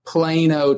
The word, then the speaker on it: Plano: So are you originally from, do you pronounce it Plano or Plano? – Plano, Texas. – Plano.